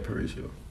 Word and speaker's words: parietal: You said it right, parietal.